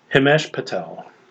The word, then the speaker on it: Himesh Patel: Himesh Patel.